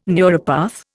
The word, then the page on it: neuropath: Indian